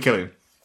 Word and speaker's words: Cillian: Every different person, a different pronunciation. – It's definitely Killian.